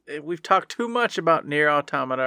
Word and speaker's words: Nier Automata: No, it's Nier Automata. Stop it, everyone. Everyone else in the world, I know you know. Everyone else in the world who doesn't know, that's how you pronounce it.